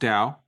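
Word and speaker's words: Tao: We learned that it's pronounced Dow.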